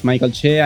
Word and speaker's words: Mike Alche: Yeah, you pronounced it perfectly. My name is Mike Alche.